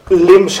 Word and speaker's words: LIMS: Nou ja, als het over LIMS gaat, want zo spreken we dat dan vaak uit, maar dat is inderdaad een afkorting voor dit systeem.